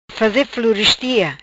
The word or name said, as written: faze florestia